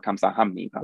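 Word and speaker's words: kamsahamnida (감사합니다): Even the word for thank you in Korean, right, is kamsahabnida, but we just, when you pronounce it, it's for kamsahamnida.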